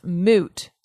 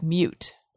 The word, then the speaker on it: moot: Ray Morgan says, annoying when people pronounce moot, like a moot point, as mute.